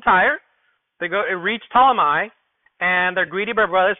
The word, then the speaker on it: Ptolemy: When they completed our voyage from Tyre, we reached, I don't even know how to say it, Ptolemy, I think it is. I know the P is not pronounced, so I'm not sure, but it doesn't matter.